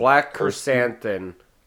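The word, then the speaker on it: Black Krrsantan: Black Kersanthan. – I was gonna say there's like 3 R's in a row, and I can never pronounce it properly.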